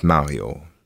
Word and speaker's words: Mario: You spell that C-A-L-V-I. My first name is Mario. That's M-A-R-I-O.